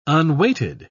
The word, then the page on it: unweighted: British English